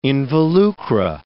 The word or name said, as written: involucra